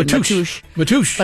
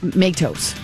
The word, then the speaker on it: Matos: So I'm getting a lot of this on the texting line, and they're saying it's out of respect, it's the Portuguese pronunciation. – Matush. – But I think a lot of people are saying Matos.